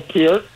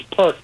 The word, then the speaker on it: Peart: Neil Peart. No, it's not Peart. It's Peart. No, it's Peart. – No, it's Peart. – It's Peart. You said it right.